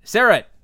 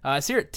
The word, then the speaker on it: Seerat: And we have our guest today, Seerat.